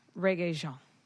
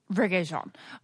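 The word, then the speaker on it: Regé-Jean: Reggae Jean. – Reggae Jean.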